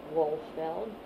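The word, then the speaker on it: Wohlfeld: His name is Alvin Wolfeld. – Would you spell it? – W-O-H-L-F, as in Frank, E-L-D.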